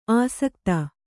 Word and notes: ಆಸಕ್ತ: āsakta

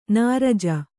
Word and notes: ನೊರಜು: noraju

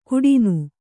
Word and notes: ಕುಡಿನು: kuḍinu

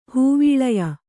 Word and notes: ಹೂವೀಳಯ: hūvīḷaya

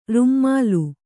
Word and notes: ರೂಮಾಲೆ: rūmāle

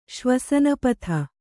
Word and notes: ಶ್ವಸನಪಥ: Kannada

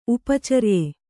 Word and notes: ಉಪಚರ್ಯೆ: upa carye

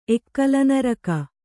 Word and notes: ಎಕ್ಕಲನರಕ: Kannada

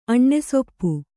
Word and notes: ಅಣ್ಣೆಸೊಪ್ಪು: kannada

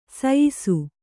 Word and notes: ಸೀಯಿಸು: sīyisu; Kannada

